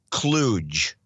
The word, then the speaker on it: kludge: This was a kludge. – Oh. – The old term. Kludge. – Kludge. Is that with a D-G-E? – Yeah, it's K-L. K-L-U-D-G-E is the proper pronunciation.